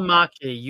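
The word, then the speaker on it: Välimäki: How do you say his last name? – Valamaki. Yuso Valamaki.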